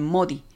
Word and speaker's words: Modi: Sus amigos le decían Modi, como abreviatura de su apellido, palabra que se pronuncia en francés de la misma manera que la palabra Maudit, que significa maldito.